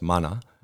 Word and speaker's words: Mana: And it's called Manas. I pronounce it properly because it's a Maori word, but it's Mana.